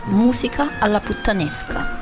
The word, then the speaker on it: Musica alla Puttanesca: And who would like to do the pronunciation for me? Because I've not got it right so far, I don't think. – It's Musica alla Puttanesca. – Ah. – It's an Italian title.